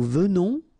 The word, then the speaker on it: venons: Nous venons. Okay, so final S not pronounced, and then remember that you pronounce this like a the, venon. Okay, O-N in your nose, on, venon, nous venons.